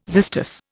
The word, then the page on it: xystus: Indian